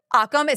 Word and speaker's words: Occam: How do you say it? – Occam. It's O-C-C-A-M apostrophe S. – There's not an L in that word? – No. – I'm going to forget this as soon as... – It's okay. – Say it again. – Occam. Occam's razor.